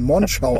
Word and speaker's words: Monschau: Achtung, kurzes O und als hätte es zwei N. Monschau aussprechen. – Monschau.